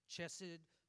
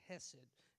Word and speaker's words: hesed: I've heard some people say hesed, chesed, hasid, hesed, but I think the proper pronunciation is hesed, and it's H-E-S-E-D.